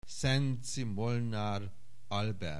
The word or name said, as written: Szenczi Molnar Albert